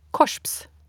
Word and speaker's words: korps: Korps uttales korsps.